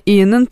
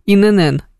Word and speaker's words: ИНН: Вот, еще многие, включая меня, ИНН произносят как ИННН.